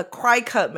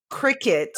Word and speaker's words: Cricut: I pronounce it wrong on purpose. Yeah. I say a cry cut machine, but I know it's Cricut because the logo has a C with little like bug antennas on it.